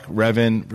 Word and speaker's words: Revan: and is that how you say it? – Malik, Revan, for sure.